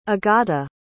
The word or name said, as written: agata